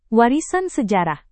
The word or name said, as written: warisan sejarah